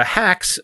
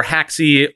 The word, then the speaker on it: Haxe: Um, but um, uh, Hacks or Haxe or Axe or it, there's no official way to pronounce it.